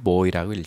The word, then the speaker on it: buoy: B-U-O-Y. 이걸 Buoy라고도 읽기도 해요. 근데 보통은 Buoy라고 읽죠.